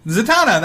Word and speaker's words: Zatanna: Take out an N. – Zant. – No, not that. Take out that N. – Zatanna? – Zatanna, that's okay. – Right. – Oh, okay, sorry. I'm saying it wrong, okay.